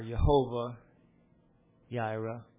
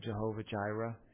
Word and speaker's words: Jehovah Jireh: Genesis 22 and verse 14, and Abraham called the name of that place Jehovah Jireh, or Jehovah Yaira, as it's pronounced in Hebrew with a soft on the Y sound on the J. In our language, we say Jehovah Jireh.